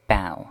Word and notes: bough: British English

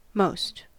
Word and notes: most: American English

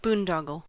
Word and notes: boondoggle: American English

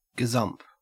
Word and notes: gazump: Australian English

